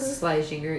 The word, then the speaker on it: Schlesinger: Eliza Schlesinger? – Schlesinger, yeah.